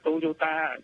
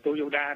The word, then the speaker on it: Toyota: À, không, thực ra là Toyota chứ không phải Toyoda đâu. Nhưng mà bởi vì chữ ta với chữ đa đó là cái chữ điền đó, thì có khi nó đọc là âm ta, có khi đọc âm đa.